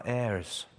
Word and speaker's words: heirs: There are heirs. I don't know how you pronounce that in Canadian, but we don't pronounce the H in English.